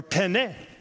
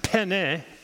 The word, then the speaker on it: penne: In Italian, when the word is just two syllables, the stress is usually put on the first syllable. So that's why it's penne, not penne.